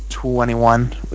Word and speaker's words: 2NE1: It's 2NE1 is how they're pronounced, but I'll talk about it next week.